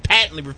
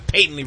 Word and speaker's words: patently: I patently refuse to in the way I use the long A instead of the short A. I don't say it patently. I patently refuse to.